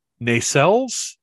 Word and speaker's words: nacelles: And I'm looking now, and there are some pictures where because in my brain, the nacelles, is that the correct pronunciation? – It is. Yep.